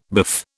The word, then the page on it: biff: British English